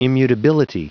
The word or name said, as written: immutability